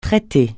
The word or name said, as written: traiter